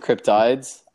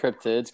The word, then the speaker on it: cryptids: where some people pronounce it cryptides. I like cryptids.